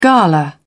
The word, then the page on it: gala: British English